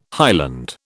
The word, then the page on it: highland: British English